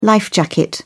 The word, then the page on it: life jacket: British English